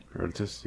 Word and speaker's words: Herodotus: Herodotus, am I saying that right? – Herodotus, yeah.